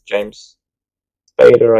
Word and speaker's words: James Spader: works with James Spader, I think I'm saying that right, as Yeah, you are.